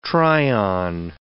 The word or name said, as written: try-on